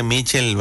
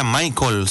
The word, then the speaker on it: Michael: Oigan, pues yo la mera verdad, yo pronuncio como yo entiendo, pues como dice aquí en la lista de la programación, me aparece Mitchell, ¿verdad? Mitchell Salgado, pero me corrigen y me dice, es Michael, se pronuncia Michael Salgado.